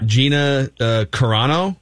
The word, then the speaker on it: Gina Carano: Gina Carano.